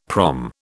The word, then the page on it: prom: British English